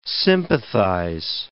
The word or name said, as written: sympathize